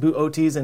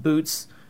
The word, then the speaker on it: Boötes: And yes, it is pronounced Boote's and not Boots, which is something I'm going to really struggle with in this video.